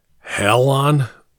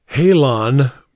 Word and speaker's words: Halon: "Hell on!" the lieutenant read aloud, the legend stenciled on the tank, pronouncing it with a long A. "Halon," Broadhead corrected him using a short A.